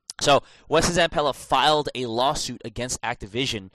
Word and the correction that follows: Activision: Wow, I put the stress on the wrong syllable there. – Activision. – Activision, like you say, umbrella, insurance.